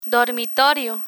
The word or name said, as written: dormitorio